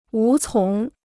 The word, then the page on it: 无从: wú cóng